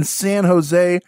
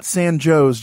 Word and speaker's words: San Jose: We're talking about the Winchester Mystery Mansion, this beautiful, beautiful mansion in San Jose, California, not San Jose, John, San Jose.